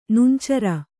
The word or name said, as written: ನುಂಚರ